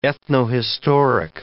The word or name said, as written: ethnohistoric